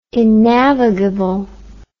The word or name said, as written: innavigable